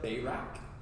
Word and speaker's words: Barak: And so today we're going to look at Barak. And so some people will pronounce it Barak. You're allowed to do that.